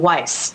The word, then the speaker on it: Weiss: The best product that I have come across in 15 years is Million Dollar Consulting by Alan Weiss, rhymes with ice.